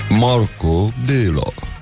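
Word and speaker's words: Markó Béla: Gloriosul zilei um, astăzi um, este Marco Bello. Nu? Am pronunțat corect? E, ia corectează-mă tu. Deci cum se pronunță? Stai, stai, stai. Marco Bello. Merci.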